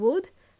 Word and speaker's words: would: Remember, could and would, L is silent. We don't pronounce the L.